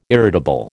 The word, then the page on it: irritable: American English